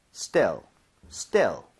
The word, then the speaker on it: still: So steal 이렇게 E 이러면 안 돼요. 얘는 입모양 벌리지 않은 상태로 편하게 갈게요. 두 번 갑니다. Still, still, still, still.